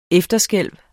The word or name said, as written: efterskælv